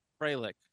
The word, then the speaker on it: Fralic: F-R-A-L-I-C. – Frelick. Oh, it's Bill Frelick. – Frelick.